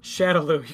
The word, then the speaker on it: Shadaloo: Shadaloo. You keep saying it wrong. – Is it Shadaloo? – Shadaloo. Yeah, I wrote it down. – Well, you said Sadaloo before. – I said Shandaloo, and then I corrected myself.